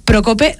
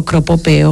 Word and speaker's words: Procope: De café Procope, ¿lo he dicho bien? – Vaya, la primera. – Le he confesado antes que siempre digo Cropope o cosas similares porque pues hay palabras que se me cruzan.